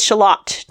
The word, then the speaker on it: Shalott: It is Shalott to rhyme with Camelot. You have to. – That makes sense. – The rhyme scheme doesn't work if you pronounce it Shalott like the thing in the Onion family.